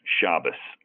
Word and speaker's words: Shabazz: The way my grandfather pronounced it was like the Z was like an S, Shabas.